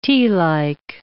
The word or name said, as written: tealike